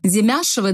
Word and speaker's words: Демяшева: И всем известно, что на прошлой неделе судья Василия Островского суда Санкт-Петербурга Оксана Демяшева, да, правильно я ставлю ударение? – Да.